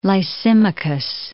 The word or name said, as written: Lysimachus